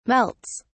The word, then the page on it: melts: British English